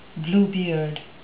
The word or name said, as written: bluebeard